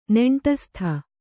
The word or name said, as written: ನೆಂಟಸ್ತ